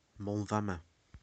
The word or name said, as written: Moel Famau